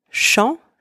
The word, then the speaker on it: chant: Second one, chant, spelled C-H-A-N-T, means song, or like the act of singing. In this word, the letters C and H together are also pronounced sh. The letters A-M together, en. The letters A-N together are pronounced en, and the final consonant T is silent.